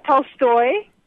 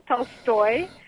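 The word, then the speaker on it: Tolstoy (Толстой): Tolstoy. That accent falls on the second syllable, Tolstoy, and Ivan Illich.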